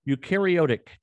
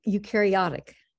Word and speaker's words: eukaryotic: She is professor of biology emerita at Washington University in St. Louis, where she engaged in research on eukaryotic. Did I pronounce that right, Ursula? – Almost eukaryotic.